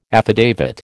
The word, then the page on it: affidavit: American English